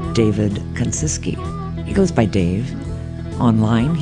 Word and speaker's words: Kuncicky: K-U-N-C-I-C-K-Y, Kanciski is how you say it.